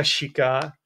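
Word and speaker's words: Schick: Já mám Patrika Šika, který se píše se S, protože to, i když se to čte se Š.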